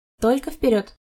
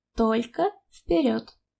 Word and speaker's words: Только вперед: Только вперед. – What did you say about a talking parrot? – No, you are mispronouncing. Listen. Только вперед. It means only forward. Только is only, вперед is forward.